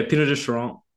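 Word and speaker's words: Pineau des Charentes: But, you know, the one that I was definitely looking for most was the, you'll pronounce this better, the Pinot de Charente. – Yeah, Pinot de Charente. I'm not Charente. French, so Charente, yeah.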